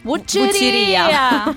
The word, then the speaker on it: Vucceria: Ci sarà l'Ebe di Canova, che viene concessa in prestito dai musei civici di Forlì, Abbiamo la Vucire di Renato Guttuso, che è proprietà dell'Università di Palermo. Avrò sicuramente sbagliato. – Vucceria! – Ah, la Vucceria, non vedo l'accento, ragazzi.